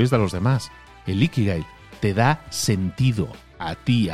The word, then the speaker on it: Ikigai: Tu iki, no sé si lo digo bien, creo que es Ikigai. No, creo que es con acento en la primera I, digamos, con, inflexión en la primera I. Creo que es tu Ikigai.